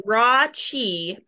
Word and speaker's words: Rathjen: So it's raw chi, but just think Okay. – of raw cheese.